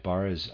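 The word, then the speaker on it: Barrès: Readers aside, Barres, I'm pronouncing it that way, is spelled B-A-R-R-E with a grave sign over it, S,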